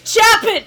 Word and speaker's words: Chapin: For the longest ass time, I mispronounced Clifford Chapin's last name, because my thought process was Charlie Chaplin. I was like, it has to be similar, right? No.